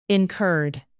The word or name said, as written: incurred